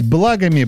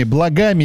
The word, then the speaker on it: благами: Как правильно говорить? Благами, благами или допустимы оба варианта? – Благами.